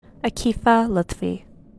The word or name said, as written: Aakifah Luthfee